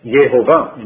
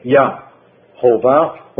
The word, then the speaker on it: Yehovah: In Hebrew you cannot say Yahovah or Yahweh. That's grammatically an error. Yah comes only at the end, second syllable, but never is the first. And so it's Yehovah, it's Yehoshua, not Yahshua, but Yehoshua. The Yah, the Ah sound changes to Yeh.